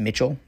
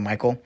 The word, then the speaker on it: Michel: It's spelled M-I-C-H-E-L, so I'm saying it's Mitchell instead of Michael.